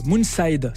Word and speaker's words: Moonside: Un label, Moonside, c'est comme ça qu'on prononce ? – Exactement.